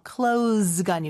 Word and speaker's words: close: Close가 아니고 close. S로 꼭 발음을 해주셔야 가까운이라는 뜻의 표현이 됩니다.